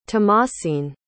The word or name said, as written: tamasine